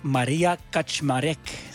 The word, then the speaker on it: Kaczmarek: Ze heet Maria Kaczmarek.